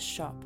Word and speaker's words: scop: I act as a shop, an old English word spelled S-C-O-P, but pronounced like retail shop.